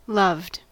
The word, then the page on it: loved: American English